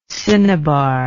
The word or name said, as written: cinnabaric